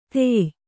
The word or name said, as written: ಧೇಯ